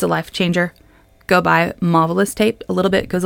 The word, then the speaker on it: Mavalus: It's called Marvelous Tape. It's spelled M-A-V-A-L-U-S, like marvelous, but with a mauve.